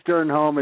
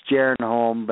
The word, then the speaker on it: Sternholm: Dwayne Sternholm is the American way. The Danish way to pronounce my surname would be Sternholm, but nobody can say that, so we just say Sternholm.